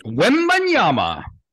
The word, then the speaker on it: Wembanyama: Wembanyama. – Do that again. – Wembanyama. – Wembanyama. – Yep. – Victor Wembanyama. – Oh, you nailed it. Good job. – Wembanyama. – Wembanyama.